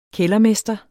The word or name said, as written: kældermester